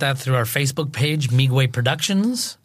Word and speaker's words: Meegwai: Miigwe, M-E-E-G-W-A-I, is a completely made-up word.